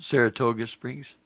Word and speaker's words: Saratoga Springs: It was a big concert that a bunch of people came to, and they called out every – it was actually in Sarasota Springs right there in Utah County. Am I saying it wrong? – No, Saratoga Springs.